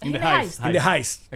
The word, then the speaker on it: In the Heist: Que no, alguien nos dijo que es In the Heist. – In the Heist. – Es como Money Heist. – Exacto. In the Heist. Se pronuncia así.